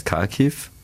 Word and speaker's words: Kharkiv: Katja ist 28, kommt aus Kharkiv.